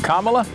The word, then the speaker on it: Kamala: Kamala? Nahmala. You always can know how it rhymes with nah.